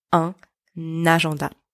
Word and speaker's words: un agenda: And for my French pronunciation made easy students, notice the liaison in that sentence. Elle achète un agenda.